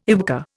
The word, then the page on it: YWCA: Indian